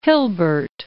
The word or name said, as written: Hilbert